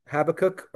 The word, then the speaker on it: Habakkuk: In one of my favorite books, Habakkuk or Habakkuk, either one is acceptable pronunciation.